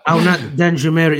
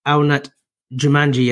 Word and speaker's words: Arnaud Danjuma: But Arnaud Danjoumer is the name. That's how you pronounce it. No. Arnaud Danjoumer is the name. I actually pronounced it as Arnaud Jumanji yesterday.